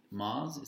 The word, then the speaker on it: Maz: Not only that, is the scene with Maz, is that how you say her name? – Uh-huh, uh-huh.